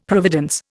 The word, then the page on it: providence: Indian